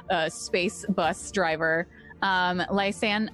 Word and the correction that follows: Lysandra: uh, sorry, I keep saying her name wrong. Lysandra,